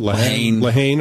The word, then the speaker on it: Lehane: It's based on a Dennis Lehane novel. Is that how you pronounce it? – Lehane. – Okay. Yeah.